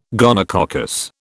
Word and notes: gonococcus: British English

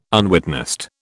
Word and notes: unwitnessed: British English

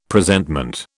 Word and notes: Presentment: British English

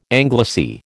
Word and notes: anglice: American English

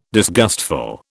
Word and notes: disgustful: British English

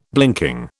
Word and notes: blinking: British English

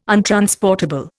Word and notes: untransportable: Indian

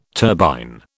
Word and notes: turbine: British English

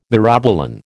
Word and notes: myrobalan: American English

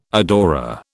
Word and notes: adorer: British English

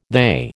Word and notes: they: American English

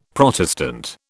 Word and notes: Protestant: British English

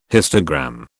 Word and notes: histogram: British English

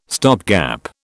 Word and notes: stopgap: British English

